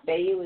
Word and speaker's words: Fehu: Now, Fayu is actually spelled F-E-H-U. But it's pronounced Fayu. – Fayu. – Right. Fayu. F-A-Y and then H-U.